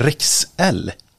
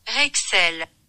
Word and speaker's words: Rexel: Och här kommer då franska uttalet. – Rexel. – Ja. Rexel. – Rexel.